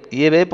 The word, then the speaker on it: Yahvé: Yahvé porque esa parece ser que la más correcta traducción del nombre de Dios, del tetragrama, en el hebreo arcaico, pues parece ser que es la pronunciación Yahvé.